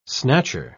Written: snatcher

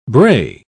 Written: bray

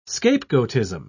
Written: scapegoatism

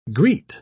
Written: greet

